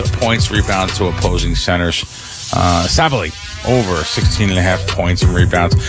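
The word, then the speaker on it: Sabally: Am I saying it right? Sabali. – Sabali, yeah.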